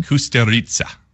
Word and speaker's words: Kusturica: Kusturica.